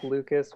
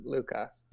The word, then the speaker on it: Lucas: Yeah, it's like Lucas with a Spanish pronunciation, Lucas.